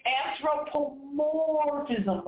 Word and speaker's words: anthropomorphism: It's anthropomorphism.